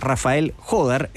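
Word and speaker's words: Rafael Jódar: Así se pronuncia, Rafael Jodar, el español, que es 11 del mundo con 19 años.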